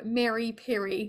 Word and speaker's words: Miri Piri: And then he decided to move to India to dedicate his life's work to help build and grow Miri Piri. Is that how you say it? – Yeah, correct.